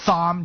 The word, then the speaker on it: Thom: Here's Thom Tillis. I'm going to put the H back in. No one pronounces the H. I'm pronouncing the H on the Marlowe Show.